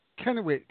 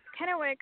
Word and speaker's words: Kennewick: Okay, is that Kennewick? Because have I pronounced that correctly? – Yes, Kennewick.